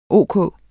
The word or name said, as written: ok